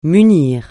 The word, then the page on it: munir: francais